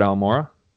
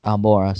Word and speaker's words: Almora: Alomar? Is that how you pronounce it? – Al- Albert Almora. – Almora, sorry.